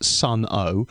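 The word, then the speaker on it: Sunn O))): A band called, and I think it's just pronounced Sun O, and it's S-U-N-N-O, close bracket, close bracket, close bracket. Sun O are at the Prospect.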